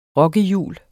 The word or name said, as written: rokkehjul